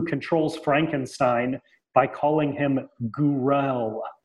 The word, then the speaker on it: Gouroull: It's something like Gourl. It's spelled G-O-U-R-O-U-L-L. Gourl. – Gourl.